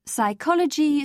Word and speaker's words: psychology: In the words psychology, psychic and psychiatry, you must never sound the P. Psychiatrist, you call the man who cures the complex, if he can. In architect, C-H-I is K.